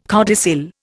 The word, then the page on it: codicil: Indian